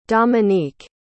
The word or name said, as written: domonique